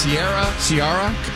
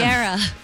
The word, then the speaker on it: Ciara: We announced Smashing Pumpkins, Ciara. I said it wrong again. – Ciara. – Yeah, exactly. The way she says it when she starts a song.